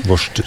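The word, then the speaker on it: Worcester: Vorstersaus, ja. Kan du si. – Vorster? – Ja. – Vorster.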